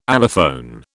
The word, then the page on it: allophone: British English